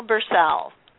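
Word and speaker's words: Bersell: It rhymes, so Michelle Barsale.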